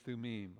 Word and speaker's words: Thummim: Now, I know some people say Urim and Thummim. Hebrew, the I is an long E sound, so it's Urim and Thummim.